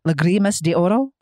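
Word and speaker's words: Lágrimas de Oro: Lagrimas de Oro. Is that how you pronounce it? – Lagrimas? – Lagrimas de Oro.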